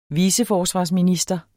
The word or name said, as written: viceforsvarsminister